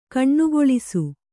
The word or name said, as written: ಕೊನೆಗೊಳಿಸು